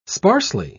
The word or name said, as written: sparsely